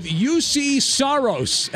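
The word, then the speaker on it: Juuse Saros: I'm going to go with UC Saros as my first pick.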